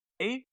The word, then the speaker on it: Mei: And people are trying to get the character May, or I believe that's how her name is pronounced, MEI.